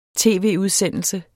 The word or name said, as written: tv-udsendelse